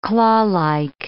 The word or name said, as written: clawlike